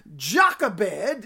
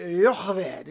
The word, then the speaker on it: Jochebed: Jochebed is the English pronunciation of it. The Hebrew would be Jochebed, Jochebed.